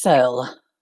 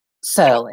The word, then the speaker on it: Searle: Searle. It's Searle. S-E-A-R-L-E, Searle. It's easier than it looks.